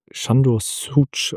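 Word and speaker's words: Sándor Szűcs: Berichtige mich bitte, wenn ich es falsch ausgesprochen habe. – Ja, ich glaube, der Mann wird Sándor Súcs ausgesprochen, aber ich bin auch jetzt nicht der Beste.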